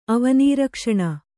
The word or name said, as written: ಅವನೀರಕ್ಷಣ